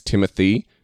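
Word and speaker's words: Timothée: Of course, it's Timothy. His name is spelled T-I-M-O-T-H. E like cafe. – Yeah. – And then another E after it.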